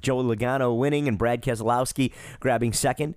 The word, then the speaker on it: Keselowski: By the way, did you notice how I pronounce his last name? Keselowski. When his family was running trucks back in the 90s and early 2000s, it was pronounced correctly then. Now, listen to the guys on TV. They all slur Keselowski. It's four syllables, gang, not three.